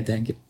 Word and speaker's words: dengue: Est-ce qu'il faut s'en inquiéter et est-ce que moi je pourrais l'attraper ici à, à Bordeaux La dingue. La dingue. C'est un E mais on prononce comme I comme le être fou, même si ça n'a rien à voir.